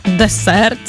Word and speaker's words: десерт: Десерт? Тістечко взагалі? – Ні, ну це таке коварне, тому що наголос так і залишається десерт.